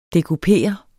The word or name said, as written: dekupere